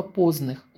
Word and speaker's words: позных: В Иркутске, особенно в центре города, огромное количество позных, ударение на первый слог, столовых или небольших кафе, где они прочно закрепились в качестве основного блюда в меню.